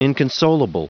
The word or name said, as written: inconsolable